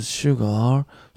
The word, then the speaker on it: sugar: Is sugar sold at the store? ごめん、今発音悪かった。 Sugar っていうのはね、 AR なんだけれども、 ER と同じ発音します。